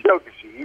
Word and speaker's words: Chokachi: Chokachi.